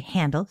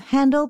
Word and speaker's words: Handel: Some people say Handel, some say Hindle, some say Handel. I personally go with Handel because he was German, but he moved to England and spent like the whole rest of his life there, and that's how they pronounced it there. So I'm going with that.